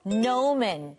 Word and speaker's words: gnomon: 자, 옆에 페이지는 sundial, 자, 해시계, 그리고 gnomon. 친구들, 여기 영어로는 발음할 때는 G가 안 들려요.